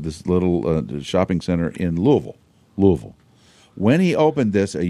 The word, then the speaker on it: Louisville: Louisville. Is that how you say it? – Sometimes it actually sounds like Louisville. – Louisville.